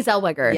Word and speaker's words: Zellweger: Zellweger? Is that how you say it? – Yes, Renee Zellweger.